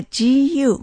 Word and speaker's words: 古: 古の発音は GU、無機音、三声です。